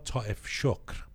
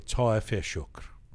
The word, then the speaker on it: Taefeshokhr: There's a new paper, a new academic paper um, written by a few people, including the scientist Dr. Nima Taefeshokhr. Mm-hmm. Sorry, Taefeshokhr.